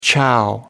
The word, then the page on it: chow: American English